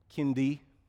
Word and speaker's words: Kinde: So my last name is spelled K-I-N-D-E. So there, I said Kindy, like kindergarten.